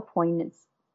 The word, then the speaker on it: poignancy: Emily Henry does a beautiful job of weaving together a light-hearted beach read with all the poignancy. The G is silent, right? – Mm-hmm.